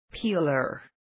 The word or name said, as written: peeler